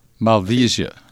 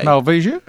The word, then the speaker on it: Malvasia: Well, you can pronounce it a number of ways. – How do you spell it for those that are listening? – M-A-L-V-I-S-I-A. – Sorry. – Malvasia, is it? – You can go both ways.